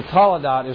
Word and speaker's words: Toledot: T-O-L... E-D-O-T. I had to think about it. T-O-L-E-D-O-T. And some people put an H on the end. So some people pronounce it Toledot.